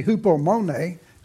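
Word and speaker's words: hupomone (ὑπομονή): And so when you look at the word here, a hupo, because the word would be hupomone, is the way it's pronounced.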